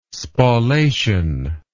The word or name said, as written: spallation